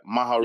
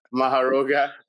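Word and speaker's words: Mahoraga: This was Sukuna versus Maroga. Yeah, Maharoga. – You said it right. You said it right. You've been practicing. – Yeah. Maharoga.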